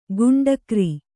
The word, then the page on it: ಗುಂಡಕ್ರಿ: kannada